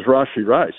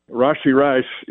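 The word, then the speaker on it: Rashee: You know, one of the missed ones is Rashi Rice. You just had him on with the promo, and it's not Rashi, and you don't wait on the first syllable like it's Rashi Rice.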